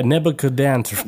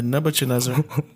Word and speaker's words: Nebuchadnezzar: How do you say this word right here? Nicocabansner. Somebody on the... Nebuchadnezzar. – Nebuchadnezzar? Nebuchadnezzar. – Nebuchadnezzar. – Yeah, Nebuchadnezzar. Because it says Chad in the middle.